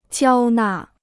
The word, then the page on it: 交纳: jiāo nà